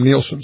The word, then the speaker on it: Nielsen: I think you say her name, Nielsen.